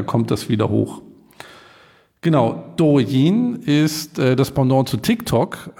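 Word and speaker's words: Douyin (抖音): Das letzte Unternehmen, was ich mitgebracht habe, ist Douyin. Jetzt korrigiere ich mich selber, weil ich gemerkt habe, dass es nicht richtig ausgesprochen wird. Das letzte Unternehmen ist Douyin.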